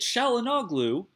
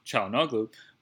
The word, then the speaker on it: Çalhanoğlu: And after Bilia got hurt, because Bilia was going to start, God help us all, we knew that Çalhanoğlu, that's how you say his name, Çalhanoğlu, was going to start in the Regista role, which he's never played in his life.